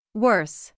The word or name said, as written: worse